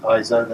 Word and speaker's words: ISO: You're okay if you pronounce it ISO. That seems to be more of a European thing.